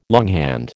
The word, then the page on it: longhand: American English